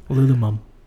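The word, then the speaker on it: Lululemon: Which solves the Lulu mom problem of not wearing the right size pants. – I think you're pronouncing that wrong. – Lulumum.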